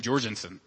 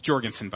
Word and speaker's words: Jorgensen: and then they pronounce my last name Jorgensen. It's Jorgensen, by the way. But when they pronounce it Jorgensen, that undercuts the accolades a little bit. I'm like, wow, you knew a lot about me, but you didn't know how to say my name.